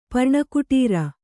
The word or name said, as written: ಪರ್ಣಕುಟೀರ